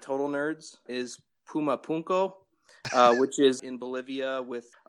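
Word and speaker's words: Puma Punku: Puma Punko with my Latino accent. That's excellent pronunciation.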